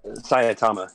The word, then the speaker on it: Saitama: I just wish it focused more on the main character, Saitama. Is that how you say his name? – Saitama, yeah.